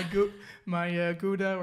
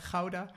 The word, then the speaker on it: Gouda: I missed my Gouda, or as it should be pronounced, Gouda.